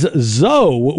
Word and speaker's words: Zoe: Well, the other thing that's going on is Zoe, which rhymes with go, of course.